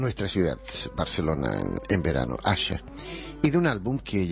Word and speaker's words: Asa: Su nombre se escribe A-S-A, pero se pronuncia Asha.